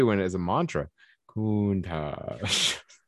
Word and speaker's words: Countach: Countach. Is that how you say it? – Countach.